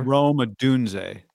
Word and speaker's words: Odunze: Odunze? Is that the wide receiver? – Rome Odunze.